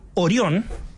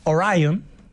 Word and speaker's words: Orion: Así que ya saben, para no perderse esta conversación, en momentos en que el mundo está observando la misión de la cápsula Orión, o como diría la NASA, Orion. Claro, porque es palabra griega, pero cuando se pronuncia en inglés queda distinto,